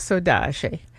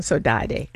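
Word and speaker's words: saudade: And I found one piece called Sodashi. And at the first, I didn't know how to pronounce it. I was saying Sodade until I met some Brazilians who explained how to say it.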